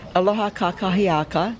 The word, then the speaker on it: Aloha kakahiaka: Aloha kakia. Blah. Got to pronounce it correctly. Aloha kakahiaka.